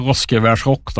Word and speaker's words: Roskvers Rock: Hur uttalar man det hela om du ska säga Roskvers, du som kan västgötska? – Ja, det är Roskvers Rock då. – Roskvers Rock, ja, bra. – Det är rätt.